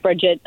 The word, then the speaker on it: Bridget: I don't know what it's just Bridget. Hi. – Okay, Bridget, how are you? I was trying to give you a fancy French pronunciation, Bridget.